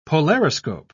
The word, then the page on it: polariscope: British English